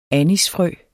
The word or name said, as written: anisfrø